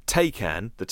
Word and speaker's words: Taycan: According to Reuters, Porsche claimed the Taycan, rhymes with bacon.